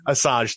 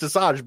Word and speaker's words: Asajj: I know that it is pronounced Asajj, but that does not look how it's spelled.